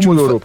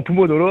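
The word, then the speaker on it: Pomodoro: Alors, Pumo Doro, précisément Pumo Doro, avec le U qui se prononce plutôt comme un OU.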